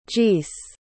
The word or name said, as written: gees